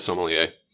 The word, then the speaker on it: sommelier: And the other thing people need to know, and we might delve into this later, is a sommelier. Did I say that correctly? – It's close enough. Certified sommelier. – Sommelier. Sorry, I dropped out that one syllable.